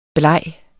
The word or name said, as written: blege